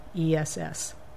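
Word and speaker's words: McGuinness: But you can find me on Twitter if you search for Kate McGinnis. And my last name, I tell people my dad picked the most complicated way. It's spelled like Guinness Stout or the Guinness Book of Records. So it's McGinnis.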